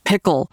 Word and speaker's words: PICL: So you've developed a machine learning tool called Pickle, which is spelled P-I-C-L, not like the pickle that you put on a burger.